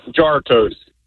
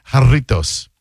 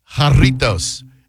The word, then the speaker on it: Jarritos: But no, it's Hispanic, so it's pronounced jarritos. – Jar toast. – As I said, jarritos, a popular Mexican brand of fruit flavored.